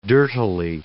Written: dirtily

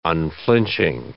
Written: unflinching

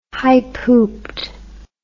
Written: high-pooped

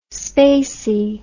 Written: spacy

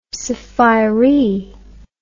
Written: sphairee

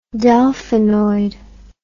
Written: delphinoid

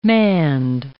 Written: manned

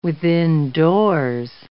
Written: withindoors

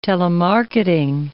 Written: telemarketing

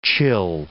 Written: chill

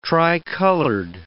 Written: tricolored

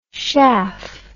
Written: schaff

